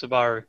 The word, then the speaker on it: Subaru: What I liked about Dean is he, he, he confirmed and cemented that uh, is pronounced Subaru.